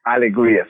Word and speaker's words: Alegria: It's Alegria, correct? – Alegria, exactly. That translates to English joy.